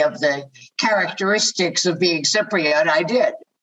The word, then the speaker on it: Cypriot: You were born in Cyprus, and is it true you consider yourself, and I want to pronounce this right, a Cypriot or a Cypriot? – A Cypriot.